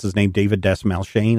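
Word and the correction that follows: Dastmalchian: Is that how you say his name? – Dasmalkian.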